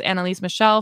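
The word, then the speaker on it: Anneliese Michel: So excuse me, but I believe her name is pronounced Annalise Michel for us English speaking people over on this side of the pond.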